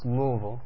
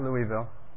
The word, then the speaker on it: Louisville: It's Louisville, in case you never knew that. Not Louisville. My mother was born and raised in Kentucky, and she taught me how to say it correctly.